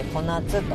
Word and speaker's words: 小夏: それでさっきの、いただいた、柑橘系の果物なんですが、ハウスの小夏、小さい夏と書いて小夏と読むんですけど、ハウス小夏というやつでしたね。